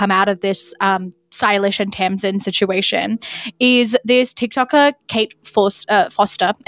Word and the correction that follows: Forster: I think is how you pronounce her last name. Forster.